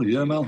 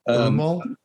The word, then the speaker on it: Euimal: How do you pronounce this? E-U-I-M-A-L? Ermal? Yermal? – Yermal. – Yermal. – Ermal? I say Ermal.